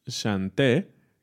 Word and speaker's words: chanter: El verbo es chanté, que se escribe chanter, ¿no?